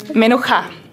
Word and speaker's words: Menucha: Ja, habe ich mir schon fast gedacht, dass ich das nicht so deutsch aussprechen darf. Okay, Menucha.